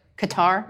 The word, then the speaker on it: Qatar: It's a hard one. It's not easy. It's like an overcompensating thing. Like people who don't speak Spanish are like Chavez. It's really Chavez. Yeah, so this is like a thing. It is Qatar, but we're like Qatar.